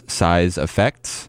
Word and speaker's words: CYESSAFXZS: Before that was Size Effects, if you can believe that's actually how it's spelled, CYESSAFXZS. That is Size Effects.